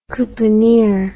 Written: couponeer

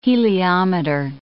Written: heliometer

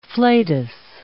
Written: flatus

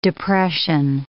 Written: depression

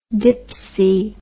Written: dipsy

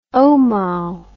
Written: omao

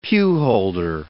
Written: pewholder

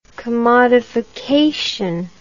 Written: commodified

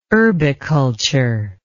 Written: urbiculture

